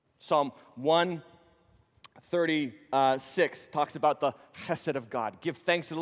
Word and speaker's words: chesed: The word in Hebrew is chesed. H-E-S-E-D. Chesed. That's how you say it. If spit doesn't come out of your mouth when you say it, you haven't said it right.